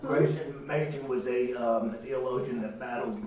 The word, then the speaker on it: Machen: M-A-C-H-E-N? – Yes. – Oh, I'm probably mispronouncing it. Yes. – That's the way I've always heard it pronounced.